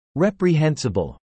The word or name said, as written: reprehensible